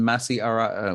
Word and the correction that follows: Masi Asare: Oh, man, I hope I'm not pronouncing the name correctly. Masi Asare.